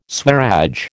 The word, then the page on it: Swaraj: American English